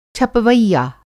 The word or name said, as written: छपवैया